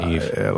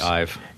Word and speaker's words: Ive: Or for Jonathan Ive. – Let me see here. – Is that how you say it? – Is it Eve or I? – It's probably Ive.